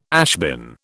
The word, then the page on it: ashbin: British English